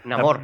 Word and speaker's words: Namor: Al Nemor, ¿no? – Namor. – Namor, perdón.